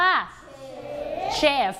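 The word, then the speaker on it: chef: ่อยไม้ทำโต๊ะทำตู้ทำเก้าอี้ทำได้หมดทำที่เกี่ยวกับไม้นั่นเองนะคะอ้าวอาชีพใหม่เพิ่มขึ้นแล้วใช่ส่วนใหญ่คนไทยก็จะเรียกทับศัพท์สมัยก่อนเราจะเรียกพ่อครัวแม่ครัวแต่เป็นภาษาอังกฤษเราเรียกว่าเชฟ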